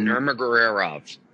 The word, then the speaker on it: Nurmagomedov: Some Nurmagomedov.